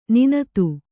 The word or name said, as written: ನುಣ್ಣಿತು